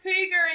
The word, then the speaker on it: hygge: Hello, and you are listening to Getting Higgy With It. Getting Higgy With It. Getting Higgy With It. Getting Higgy With It. Getting Higgy With It. – It's actually pronounced Hygge in Danish.